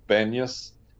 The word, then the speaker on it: Banyas: Hence, these days, the place is called Banyas, but the Arabs can't pronounce the B, so it became Panyas. Sorry, can't pronounce the P, so it became Banyas. So it's really Panyas.